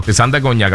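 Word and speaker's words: Tristán da Cunha: Se llama Tristán de ¿cómo es que dice ahí? De Cuncha. De Cuna. Tristán de Cuna, qué sé yo. – Cuna. – Ajá. Yo lo pronuncio bien ahora porque lo tengo por acá bien redactado. – Tristán de Cuña, creo que es. Tristán de Cuña. Pues sí.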